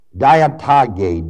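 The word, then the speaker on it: diatage (διαταγή): Ordinance is the Greek word diatage, D-I-A-T-A-G-E, long E, meaning arrangement, setup.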